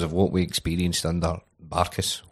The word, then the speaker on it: Barkas: Barkas. That's the way Taggart would say it, which is the official pronunciation.